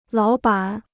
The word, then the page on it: 老板: lǎo bǎn